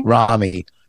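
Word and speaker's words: Ramy: Michael Che, Romney. How do you say his name? – Rami.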